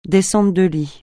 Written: descente de lit

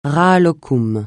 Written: rahat-lokoum

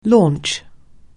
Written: launch

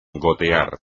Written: gotear

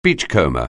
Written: beachcomber

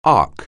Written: arc

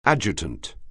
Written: adjutant

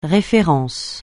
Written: référence

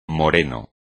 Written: moreno

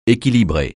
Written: équilibrer

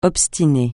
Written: obstiner

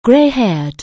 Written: grey-haired

